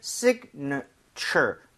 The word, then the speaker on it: signature: 그래서 앞쪽 I의 강세를 좀 주면서 사실은 signature, 그러니까 살짝 나오지만 실제로는 발음을 빠르게 들으면 거의 signature. 거의 A 발음이 거의 안 나오네요.